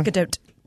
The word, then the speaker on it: Gadot: Am I saying that right? – Gadot.